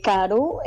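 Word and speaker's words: karu: Se llama Caru Caru. Caru se llama, ¿verdad? Tiene acento en la U, porque es una palabra guaraní que significa comer. – Sí, Caru es comer en guaraní.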